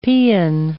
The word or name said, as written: paean